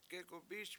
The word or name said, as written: gegumbIS